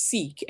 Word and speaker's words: CEEK: Yes, our platform is Seek, and it's spelled C-E-E-K.